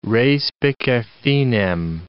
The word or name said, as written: respice finem